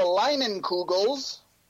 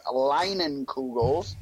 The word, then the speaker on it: Leinenkugel's: It's a Leinenkugel's. Yes, that's how it's pronounced. Leinenkugel's.